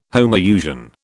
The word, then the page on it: homousian: British English